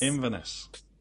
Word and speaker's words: Inverness: Inverness. – Inverness. So the emphasis is on the in.